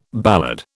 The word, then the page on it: ballad: British English